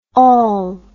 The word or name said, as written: ol